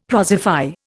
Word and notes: prosify: Indian

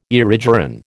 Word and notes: erigeron: American English

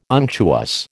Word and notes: unctuous: American English

